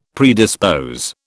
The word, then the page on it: predispose: British English